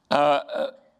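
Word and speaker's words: Lechem (לחם): Lechem, you got to say it like you're coughing over in Israel.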